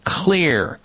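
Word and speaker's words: Xlear: There is a nasal spray on the market called Kids Clear. Clear is spelled with an X, X-L-E-A-R.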